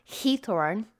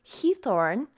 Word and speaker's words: Giethoorn: So it's Heathorn. Oh my gosh, I've watched so many different YouTube videos to try and practice that. Heathorn. It's spelled G-I-E-T-H-O-O-R-N, and I know I'm probably butchering that, so my Dutch friends, please forgive me, but it is in English, we would probably say it like Giethoorn, I guess, but it's in the Netherlands, and this place has no roads or any modern transportation at all, only canals and 176 bridges.